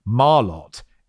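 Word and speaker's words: Malört: Marlott. It's pronounced just as it's written.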